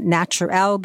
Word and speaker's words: Naturel: I'm selling it Naturel because it's spelled Naturel.